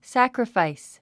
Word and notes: sacrifice: sǽkrifàis; サクラファイス

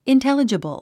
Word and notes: intelligible: intélədʒəbl; インテレジボゥ

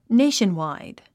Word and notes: nationwide: néiʃənwàid; ネイションワイド

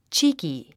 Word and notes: cheeky: tʃíːki; チーキィ

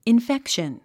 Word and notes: infection: infékʃən; インフェクション

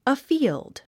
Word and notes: afield: əfíːld; アフィールド

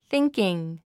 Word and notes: thinking: θíŋkiŋ; スィンキン

